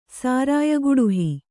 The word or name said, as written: ಸಾರಾಯಗುಡುಹಿ